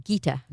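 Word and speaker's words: Geeta: Is it pronounced Gita? – Gita.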